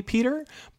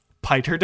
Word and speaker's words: Piter: It's spelled P-I-T-E-R, so I have to assume it was actually Peter, but both of the movies have pronounced it as Piter, so I have to assume that Frank Herbert's intention was that his name is Piter DeVries.